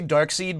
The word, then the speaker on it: Darkseid: Let's agree to say Darkseid, because that's how I always said it back in the day before someone corrected me. Darkseid.